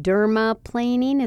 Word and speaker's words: dermaplaning: And two VI peels, two aqua facials, two prime and protection facials, including dermaplaning. Is that how you say it? – Yeah.